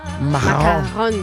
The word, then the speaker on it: macaron: E sto senti anche la pronuncia che è macaron. – Con la R devi diventare il catarro.